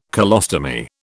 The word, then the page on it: colostomy: British English